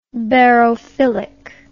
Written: barophilic

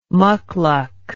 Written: muckluck

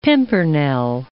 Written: pimpernel

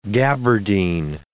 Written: gaberdine

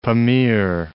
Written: Pamir

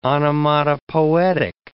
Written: onomatopoetic

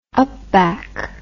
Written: upback

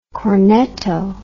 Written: cornetto

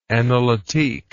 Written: analytique

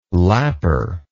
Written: lapper

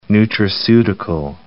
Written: nutraceutical

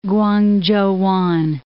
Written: Kwangchowan

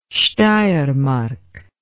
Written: steiermark

